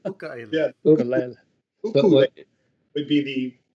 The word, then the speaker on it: ukulele: The correct way to pronounce it in Hawaiian. So it's ukulele.